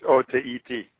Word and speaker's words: Otaheite: And he talks about these star apples and rose apples and Otaheite apples and Etiote apples. – What do you call it? – Etiote. – Actually, it's Otaheite.